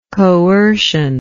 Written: coercionist

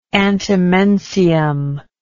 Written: antimensium

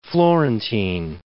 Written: Florentine

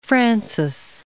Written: Francis I

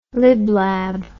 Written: liblab

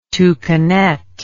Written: toucanet

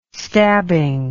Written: stabbing

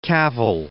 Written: Cavell

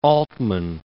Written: Altman

